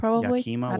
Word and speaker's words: Yakima: Around 2007, I was living in Yakima, Washington. Did I pronounce that right? – Yakima, Washington.